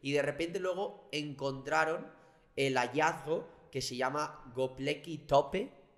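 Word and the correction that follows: Göbekli Tepe: Eh, bien está Gobekli Tepe.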